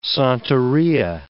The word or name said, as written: Santeria